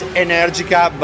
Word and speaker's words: Energica: The general pronunciation is Energica, but we are in America, so Energica, well. Energica. Yeah, it works.